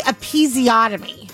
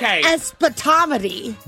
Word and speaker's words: episiotomy: He's trying to say episiotomy. – Did I not say that? Oh my God. – Not even close. You said espotomy.